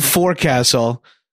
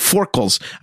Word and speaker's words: forecastle: It's spelled forecastle. the thing that they put them in the front of the ship where the water comes in. But the way they pronounce it, I could not catch it. I like even rewound. It's like forkles.